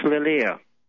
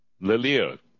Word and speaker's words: Lihir: It's spelled L-I-H-I-R. – It's pronounced Lilia. – Lilia.